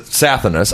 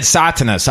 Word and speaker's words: Sathanas: Well, the bands themselves pronounce it Sathanas. I pronounce it Sathanas. What do you pronounce Sathanas. – It? – Sathanas. – And I said Satanas. I thought the H was silent.